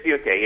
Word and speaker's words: Funke: I mean, I'm thinking both of your character Tobias Funk. Am I saying that right? Funke? That is? In Arrested Development? – It is Funke, yeah.